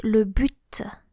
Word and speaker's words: but: The goal, the aim, is le but. Le but. It's spelled B-U-T, B-U-T, but you actually pronounce the final T, the final consonant, which is quite unusual.